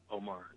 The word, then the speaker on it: Omar: Omar, yes, Mrs. Yeah.